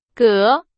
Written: 隔

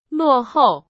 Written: 落后

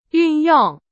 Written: 运用

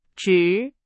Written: 直

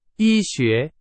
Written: 医学

